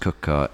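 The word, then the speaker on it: Quooker: Spelt quicker, pronounced cooker.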